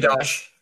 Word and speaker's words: Biadasz: Biadasz.